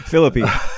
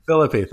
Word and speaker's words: Phillippe: For instance, the overall premise is that a character, Ryan Phillippe, I can never say his name. – Phillippe. – Phillippe, thank you.